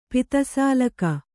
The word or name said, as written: ಪಿತಸಾಲಕ